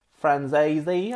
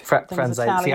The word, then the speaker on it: Franzese: Is it Daniel Franceschi? – Franzese, I think. – Franzese. I sounded drunk now when I said that. Franzese.